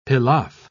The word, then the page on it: pilaff: British English